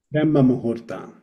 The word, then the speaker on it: Brahma Muhurta: Brahma Muta. – Brahma Muhurta.